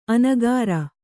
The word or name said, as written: ಅನಕರ